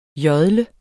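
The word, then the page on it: JODLER: Danish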